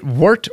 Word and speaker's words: wort: Is it wart or? – Wart. W-O-R-T. – But it's pronounced the same as when you have like a wart.